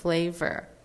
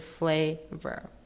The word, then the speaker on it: flavor: 那在它下一个字 flavor，它那个 a 音是发字母 a 的音。那 vor，你其实可以想象它是 ver，因为它发音就是那个 er 的音。那加起来就是 flavor，还有最后一个字 taste，它那边的 a 音也是发 a 的音。